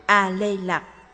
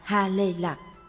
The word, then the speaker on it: A Lê Lạc: A Lê Lạc, tên gọi được phiên âm từ Phạn ngữ Haritaki, nên trong một số kinh luật cũng được đọc là Ha Lê Lạc.